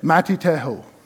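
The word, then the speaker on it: Matitehu (מתתיהו): But do you know how they say it in Hebrew? They say it, Matitehu.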